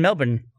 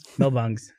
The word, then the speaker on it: Melbourne: Melbangs. – Melbangs? – It's every time someone from America says the word Melbourne, it like evolves more and more.